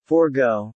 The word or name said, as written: forgo